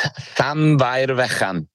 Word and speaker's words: Llanfairfechan: It's Llanfairfechan, on the foothills of the Carneddau mountain range.